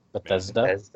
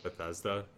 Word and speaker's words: Bethesda: Bethesda. – Oh, Bethesda. – Bethesda.